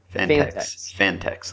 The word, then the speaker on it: Fantex: Where's the syllable break there? – Fantex. Fantex. Let's say Fantex.